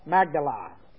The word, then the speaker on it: Magdala: Magdala is the way it's pronounced, Magdala.